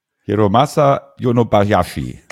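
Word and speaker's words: Hiromasa Yonebayashi: 2010 erschienen, Regie Hiramatsu Yonebayashi. Habe ich es richtig ausgesprochen? – Hiramatsu Yonebayashi. – Ganz genau, ja.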